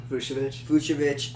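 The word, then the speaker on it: Vučević: Vojvic, I don't know how to pronounce his name. – Vucevic, Kyle Lowry, and the special player is Dirk.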